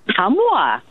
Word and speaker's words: Ottumwa: Ottumwa, Iowa.